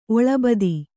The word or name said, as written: ಒಳಬದಿ